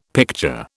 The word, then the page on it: picture: British English